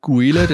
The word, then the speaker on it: Guile: Also durch den Film haben wir dann geil gesagt, aber vorher war es halt Guile, den hast du nicht aussprechen können.